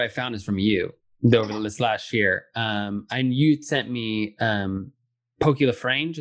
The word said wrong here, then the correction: LaFarge: is that how you say his name? – Pokey LaFarge. – LaFarge, yeah.